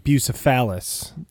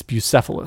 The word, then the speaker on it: Bucephalus: We used to pronounce it Bucephalus. – It's Bucephalus.